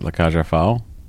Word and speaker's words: La Cage aux Folles: How do you say that? La Cage aux Folles. Any idea? – You said it the first time is how I've always heard it. La Cage aux Folles.